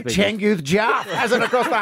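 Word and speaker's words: Changkuoth: And how's his first name go? – Well, it's Changuth, but no one's bothered to spend 3 seconds to try and say his first name, so they just throw CJ.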